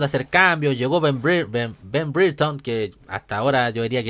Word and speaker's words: Brereton: Brereton, que suena como que el Brere suena como el here del aquí en inglés, así. Brereton.